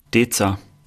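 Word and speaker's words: Deezer: Wie spricht man das richtig aus, wenn man nicht Thüringer ist? Bitte einmal sagen. Deza.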